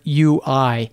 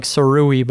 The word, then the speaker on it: Sirui: Siri is spelled Siriui. I guess it looks like Sirui, but I'm told on good authority that it's pronounced Siri.